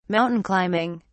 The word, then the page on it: mountain climbing: British English